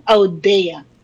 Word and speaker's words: Odia: Tama naman po ang pronounce ninyo ng aking last name, Audea.